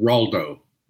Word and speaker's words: Raldo: The tough guy, did you say his name was? Ronaldo? – Raldo. – Raldo. – It's R-A-L-D-O, Raldo.